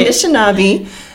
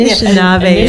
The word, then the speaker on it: Anishinaabe: I've been saying it a little bit wrong. – Okay. – Anishinaabe Medewin Society. Is that right? – Yeah. – Did I say it right? – The Anishinaabe Medewin Society.